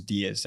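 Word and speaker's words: Díaz: And then my last name is Diaz. That Diaz is like the number 10 in Spanish. The only difference is that with Diaz, there's no emphasis on the I, so it's just Diaz.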